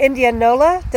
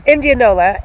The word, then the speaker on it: Indianola: All right, so we were on our way to Indianola. Did I say that right this time, do you think, Nancy? – Yeah, sounds okay to me. – Kinda, sorta. – Indianola, and had to pull over there.